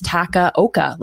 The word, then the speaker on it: Takaoka: Maybe I've been saying it wrong, right? Like maybe there's like a nuance to it that you're supposed to pronounce like the middle part of his name with an I, but no, I checked Dwight Capp's site. It's Takaoka. Like it's pretty straightforward.